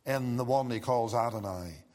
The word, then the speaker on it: Adonai: And then the second word, Lord, in the Hebrew it is pronounced Adonai.